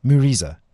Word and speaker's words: Mureza: So just to be clear, the Muriza, how do you say it? Muriza. – Muriza. – Muriza. Muriza. Yeah, M-U-R-E-Z-A.